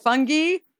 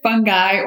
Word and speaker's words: fungi: How do you actually pronounce? Is it fungi? Or you could say fungi or fungi.